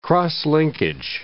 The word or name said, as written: cross-linkage